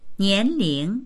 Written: nian2ling2

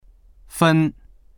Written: fen1

